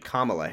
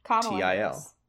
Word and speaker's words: Kamala: So do we want to talk about video that went out about Kamala? Speaking of presidential people, I do have to say, apparently it's actually Kamala Harris. – Kamala. Thank you. – T-I-O.